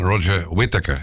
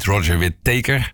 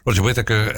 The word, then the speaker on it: Roger Whittaker: Dus dan, nou hebben we niemand minder dan Roger Whittaker. Ik zeg het goed. Hans, ik moest het per se beloven dat ik het goed zou uitspreken. En niet Roger Whittaker. Roger Whittaker, en die heeft het liedje geschreven I Don't Believe in If Anymore.